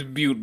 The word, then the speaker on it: Butte: Police in Butte and Yuba County began to... – it's Butte, but it's spelled B-U-T-T-E.